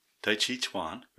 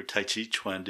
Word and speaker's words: Tai Chi Chuan: In conclusion, if you're looking for something to explain energy work and its correlation with Tai Chi Chuan or Tai Chi Chuan, depending on which pronunciation you are familiar with, I will once again recommend reading Dr. Mike's book.